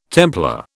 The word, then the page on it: Templar: British English